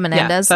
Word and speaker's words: Menendez: This, okay, I'm going to bring it back to the Melendez case. – The Menendez? – Yeah, mm-hmm. Did I say it right? – The Bros.